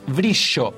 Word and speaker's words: Brillo: En primer lugar, un disco, en este caso del cuarteto de Coté, disco cuyo título se llama Brillo, pero atenti con la pronunciación de la Y, Brillo, con Y, no con doble L, el nuevo material del cuarteto de saxos.